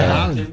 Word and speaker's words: Haaland: É Haaland, sabia? Oh. Agora eu descobri que é pronúncia. – Haaland. E o Datena falava Haaland na época e todo mundo zoava o Datena. E ele já tava. – Caramba. – Prevendo o certo já.